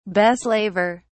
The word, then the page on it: beslaver: British English